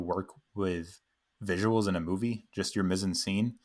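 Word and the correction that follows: mise-en-scène: It's a French term that I'm pronouncing completely wrong, but mise-en-scene. – Sure. There you go.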